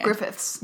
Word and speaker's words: Griffiths: Griffith. – Yes. – It's a problem because it's like ownership. So there's an S on the end. – Griffiths.